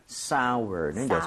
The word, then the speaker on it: sour: So sour인데 sour가 아니라 sour는 여전히 그렇게 Sour. – 아우어처럼 발음해 주시면 되겠습니다.